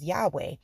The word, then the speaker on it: Yahweh: We learn that YHWH, we pronounce as Yahweh, is actually El Shaddai.